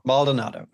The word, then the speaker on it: Baldonado: It's Baldonado.